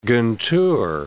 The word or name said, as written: Guntur